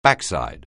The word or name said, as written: backside